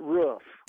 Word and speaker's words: roof: Is there anyone in the company that says it more like rough? No, he's the only one that I've ever heard pronounce it roof.